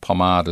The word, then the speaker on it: Pomard: D-U-N-S-T-A-N, David Dunstan's excellent book, Better Than Pomard. Better Than Pomard. Pomard is spelled P-O-M-A-R-D.